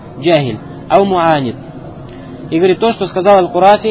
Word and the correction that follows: Аль-Карафи: или точнее Аль-Карафи, правильно говорит.